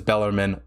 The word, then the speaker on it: Bellarmine: And yes, I know the correct pronunciation is Bellarmine, but I'm going to say it how the hell I want to say it.